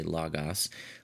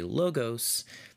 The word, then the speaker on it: Logos: But because people who aren't familiar with it might think it's L-A-G-A-S if we say Logos, we typically say Logos in other circumstances. So both are correct. Either one is fine.